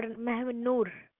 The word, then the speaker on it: Mahnoor: M-A-H-N-O-O-R. – Mahmoor. Okay, and last name? – Mobin. M-O-B-I-N. – Mobin. It is Mahmoor or Mahnoor? – Mahnoor. – N for nose, right? – Yeah.